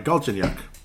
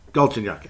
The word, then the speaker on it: Galchenyuk: As we'll finally try to say it right, Galchenyuk. Galchenyuk.